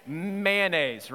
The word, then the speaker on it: mayonnaise: But, but how do you say the word, the M word? mayonnaise, right? It's not man mayonnaise. Mayonnaise. How do you, how many pronounce the O in there somewhere? Mayonnaise? Yeah, there's some people, there's some people that do.